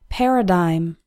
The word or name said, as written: paradigm